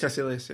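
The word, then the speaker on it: Kaecilius: Caecilius, yeah.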